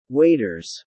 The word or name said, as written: waiters